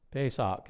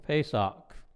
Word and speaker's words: Pesach: How do you pronounce Passover, the word for Passover? Pascha? Pesach, that's it. We were practicing this. Pesach.